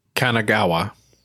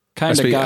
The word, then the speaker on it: Kanagawa: Local police in, good luck pronouncing that, Osio Kangawa. – Hiroshima. – Kanagawa. – Oh. – Kanagawa.